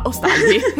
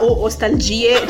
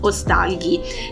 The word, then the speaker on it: ostalgia: Anche questo l'abbiamo cercato, pronuncia corretta, ostalgia. – Sì, perché prima ovviamente la nostra pronuncia era ostalgie, cioè la mia proprio para italiana, pure ostalgie, questo tipo specie di francese che non c'entra niente, ma no, si dice ostalgia.